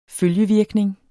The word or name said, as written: følgevirkning